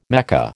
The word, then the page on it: Mecca: American English